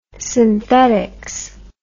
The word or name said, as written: synthetics